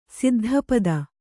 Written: ಸಿದ್ಧಪದ

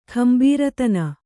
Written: ಕುಂಬಾರತನ

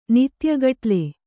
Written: ನಿತ್ಯಗಟ್ಲೆ

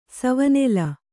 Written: ಸವನೆಲ

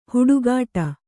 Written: ಹುಡುಗಾಟ

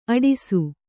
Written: ಅಟ್ಟಸ

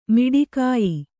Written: ಮೋಟಕೆಯ್

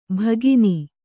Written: ಭಗಿನಿ

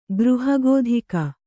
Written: ಗೃಹಗೋಧಿಕ

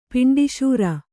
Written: ಪಿಂಡೀಶೂರ

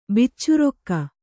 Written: ಬೇಚರಾಕ

